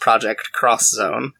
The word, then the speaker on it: Project X Zone: if you see an X in Japanese, like it's usually meant to indicate, it's usually pronounced cross, meant to indicate like versus almost, or like a crossover, like the video game Street Fighter Cross Tekken is spelled Street Fighter X Tekken, or Project X Zone, which is pronounced Project Cross Zone.